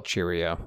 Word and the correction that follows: Chihiro: Dude, anybody who's a Spirited Away fan heard me pronounce it Cheerio and they turned off their iTunes or whatever. – Nah, dude. We're good because this is a podcast, you know? They didn't know how it was spelled. – Yeah, but once I said Spirited Away, they're like, he said Cheerio. It's clearly this motherfucker Chihiro.